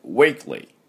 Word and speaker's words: weekly: で、 weekly、この発音ですが、 week というふうに日本人が weekly って、く、 k の後に u を入れないようにね、 weekly でありません。 weekly。